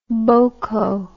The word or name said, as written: boko